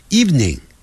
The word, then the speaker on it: evening: 好，就是三个音节，你看它就念 evening，一念这样念的话，我就马上知道你讲的不像老外，我们老外只讲两个音节 evening，这样，但不要念成 evening，是 evening，那个不的音很短。 Evening, good evening，那是晚上好。